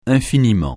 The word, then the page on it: infiniment: francais